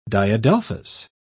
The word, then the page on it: diadelphous: British English